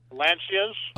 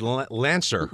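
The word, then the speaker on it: Lancia: Lancias. – Oh, okay. – It's Italian. – Yeah, well, now I thought he said Lancer, Dodge car. – No, he's pronouncing it.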